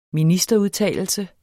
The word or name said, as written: ministerudtalelse